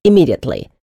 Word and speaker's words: immediately: Immediately. Only one M is pronounced, like in immortal, immigrant, committee, or commas. Unless, of course, the M's belong to two different words, as in roommate.